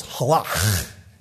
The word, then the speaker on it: halak: The Hebrew word for walk is halak. And the key to Hebrew pronunciation is here. It's in the guttural. It's halak.